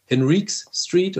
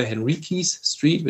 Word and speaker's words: Henriques Street: Das ist auch so ein kleiner Hof gewesen in der Burner Street, die heißt heute Henriques Street oder Henriques Street, wird unterschiedlich ausgesprochen.